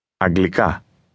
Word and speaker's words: Αγγλικά: Αγγλικά. – How do you say English? – Αγγλικά. – Did you notice that the speaker stressed the last part of that word?